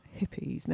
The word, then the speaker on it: Hippeas: So just coming back to you, Craig, where can people find out about Hippies? Now, it's spelled HIPPEAS, Hippies. hippies.